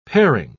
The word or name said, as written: pairing